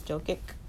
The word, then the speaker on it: Jokic: Jokic.